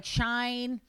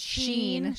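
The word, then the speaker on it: Shein: It's made of like good fabric, but like things like Shine. Is that how you say it? – Sheen.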